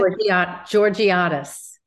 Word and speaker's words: Georgiadis: Georgiadis.